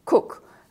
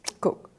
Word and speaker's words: Kuk: called Cook, and it's spelt K-U-K, Cook, a few miles from where the first Australians to come to Hagen in 1933, set up camp.